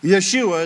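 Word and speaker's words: Yeshua (ישוע): Yeshua is how you pronounce Joshua in Hebrew.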